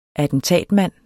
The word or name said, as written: attentatmand